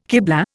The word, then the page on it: kiblah: Indian